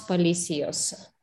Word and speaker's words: policías: Los dos policías. – Policías. Policías. – Los dos policías. – Okay, so. Este es el acento. So, el acento marca la I, larga. – Mm. – It marks the long I.